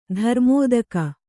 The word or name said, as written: ಧರ್ಮೋದಕ